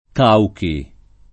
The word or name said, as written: Cauchi